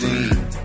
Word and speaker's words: zine: It's pronounced zine. Zine, zine, zine.